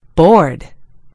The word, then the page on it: board: American English